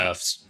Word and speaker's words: Seufz: The next one is The Longing from Studio Surfs? Surfs? S-E-U-F-Z. However you want to pronounce it. – Surfs.